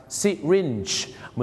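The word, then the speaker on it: syringe: ที่จริงแล้วนะครับคำว่าเข็มฉีดยาเขาออกเสียงว่าซิรินจ์นั่นเองครับซิรินจ์เ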